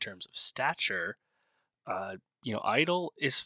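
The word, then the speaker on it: (G)I-DLE: Yes, Idol, the G is silent.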